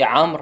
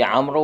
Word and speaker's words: عمرو: زين، شون مثلا الواو في عمرو، هسه بعضهم يقول عمرو، هو خطأ، هذا الواو تكتب ولا تلفظ.